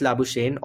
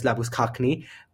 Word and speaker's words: Labuschagne: So that leaves us with number three, with Kane Williamson and Manus Labuschagne, or Manus Labuschagne, what depending on your version of the pronunciation, at number three, and Ajinkya Rahane and Babar Azam at number five.